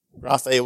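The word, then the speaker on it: graphe (γραφή): And it's another G word, graphe with a little line over it, because it's there's two versions of E in Greek, and this is the one that is the long E.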